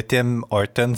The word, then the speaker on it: Tim Hortons: On dit-tu Tim Hortons ou Tim Hortons? En tout cas, moi, j'ai tendance à prononcer le S, mais en français, on n'est pas supposé les prononcer.